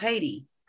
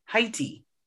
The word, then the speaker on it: Haiti: That's how I think you're supposed to actually pronounce Haiti. Haiti. – Haiti? – Haiti. – I think that's like if you're French, if you're a French speaker.